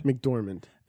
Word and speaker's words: McDormand: McDormand.